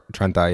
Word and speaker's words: Trenta: Black, Trenti. Is it how you say it? Trenta? – Or Trenta, yeah.